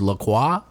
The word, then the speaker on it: LaCroix: Is that the right way is LaCroix. – Are you sure? – The French way to pronounce the word is La Croix, but the company has come out and said that it's pronounced La Croix.